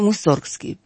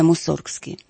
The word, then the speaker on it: Мусоргский: Если правильно ударение делать, то Мусоргский. При его жизни и до революции ударение было именно Мусоргский.